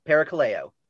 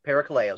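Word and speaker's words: Parakaleo (παρακαλέω): K-A-L-E-O. – All right. Does that look like what you want? – That's it. Parakaleo. Parakaleo is how you say that in Greek. Okay. Parakaleo.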